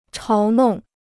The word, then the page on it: 嘲弄: cháo nòng